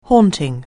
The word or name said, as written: haunting